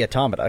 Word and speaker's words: Automata: It pronounced both ways. It must be Automata because that's... Like, that word exists. It's in computer science and that's how you pronounce it.